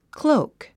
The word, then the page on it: cloak: klóuk; クローゥク